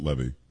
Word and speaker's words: Levy: By the way, I think it's Levy. I think Eugene says it Levy.